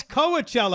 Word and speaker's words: Coachella: You went to Coachella? No. – No, it's Coachella. I like to mispronounce it like Tijuana.